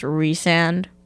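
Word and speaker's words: Rhysand: Apparently it's pronounced Resand, but I think it's Rysand.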